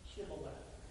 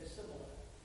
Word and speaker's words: Shibboleth: So when Jephthah slaughtered the Ephraimites, he asked them to pronounce Shibboleth, and they said Sibboleth, and they no longer spoke the same thing.